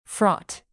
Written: fraught

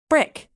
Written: brick

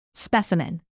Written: specimen

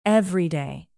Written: everyday

